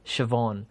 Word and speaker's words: Siobhan: But it is a traditional Irish name, and it is pronounced Siobhan.